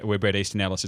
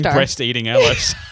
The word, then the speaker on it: Bret Easton Ellis: So, you know, which gives you a sense of where Bret Easton Ellis is coming from. And I said it right. Yay. – Yay, well done. – Because I keep saying breast eating Ellis.